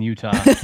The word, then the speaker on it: Utah: We have a T in Utah. – It's a soft T. – It's a soft T. – Oh, goodness. Well, I was in Utah.